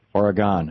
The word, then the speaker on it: Oregon: or do you pronounce it Oregon? – Oh, it's Oregon. We drew the 80. – Oregon.